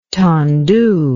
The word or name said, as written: tendu